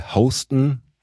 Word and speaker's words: HSTN: Und die hatten tatsächlich dann die Wayfarer, das Modell, und die Skyler hatten sie noch, und die Oakley Houston. Habe jetzt auch neu gelernt, dass das Houston ausgesprochen wird, H-S-T-N.